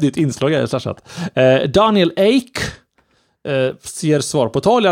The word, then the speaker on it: Ek: Daniel Ek, eller Ek heter det, som vi alla kan på svenska.